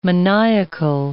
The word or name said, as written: maniacal